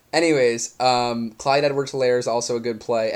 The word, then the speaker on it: Clyde Edwards-Helaire: He's like, Clyde Edwards-Helaire. It was like kind of pissing. The H is silent. – It is silent. – He said it correctly.